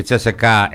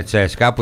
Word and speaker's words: Τσέσκα: Η Τσέσεκα έγινε Τσέσκα, που είναι και το σωστό. Ίσως είναι ένα από τα λίγα σωστά. Γιατί έτσι το προφέρανε πάντοτε οι Ανατολικοί, Τσέσκα.